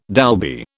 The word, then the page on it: Dolby: British English